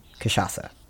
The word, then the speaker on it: cachaça: How is it different than cachaça? So, and first you have to learn how to pronounce cachaça, because it doesn't look like that.